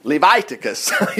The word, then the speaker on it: Leviticus: Leviticus, we miss it. Leviticus, Levi. Totally miss it when you pronounce it that way. It's Leviticus.